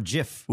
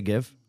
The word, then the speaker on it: GIF: The word that it represents is a soft G, so it Yogging. – Makes sense that it's JIF, but no, it's GIF.